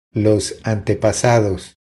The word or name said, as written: Los antepasados